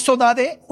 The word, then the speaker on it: saudade: Forma de decir saudade, una palabra que en Brasil conocemos mucho, saudade, saudade, es un término portugués que así se pronuncia en el archipiélago, saudade, con ese dialecto caboverdiano.